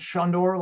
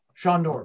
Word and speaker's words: Sonder: It's pronounced Sonder, like Sean Connery. Sonder. Sonder. – Okay. – Like Sonder.